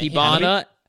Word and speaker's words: Hibana: Like you have Hinaba, or how is it pronounced? – Yeah, Hibana.